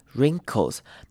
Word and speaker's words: wrinkles: wrinkle 呢，前面的 W 我们并不发音，所以只要发 R 的声音就好了。 wrinkles. 那这边比较特别的是 R-I-N，我们会念成不是 rin，不会念成短母音 I 的念法，而是像是戒指那个字 R-I-N-G， ring 那个 I-N-G ing 的声音要念出来。